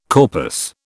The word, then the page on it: corpus: British English